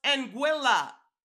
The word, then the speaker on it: Anguilla: It's pronounced Anguilla, rhymes with vanilla.